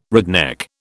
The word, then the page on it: redneck: British English